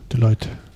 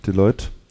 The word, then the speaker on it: Deloitte: spricht man die denn richtig aus? Deloitte, ne? – Deloitte. Deloitte.